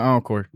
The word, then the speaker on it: Ankur: Only one Encore.